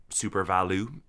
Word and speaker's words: SuperValu: I was approached in su- su- SuperValu is how I insist on pronouncing it because it doesn't have an e.